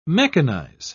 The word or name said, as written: mechanize